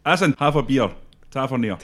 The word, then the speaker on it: Tavernier: Do you think James Tavernier is another one that other clubs might be looking at? – How do you say it? Tavernier? – Tavernier.